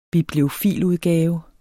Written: bibliofiludgave

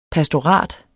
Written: pastorat

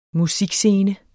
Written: musikscene

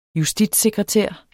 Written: justitssekretær

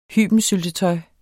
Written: hybensyltetøj

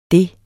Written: det

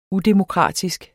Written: UDEMOKRATISK

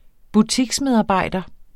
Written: butiksmedarbejder

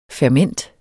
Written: ferment

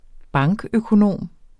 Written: bankøkonom

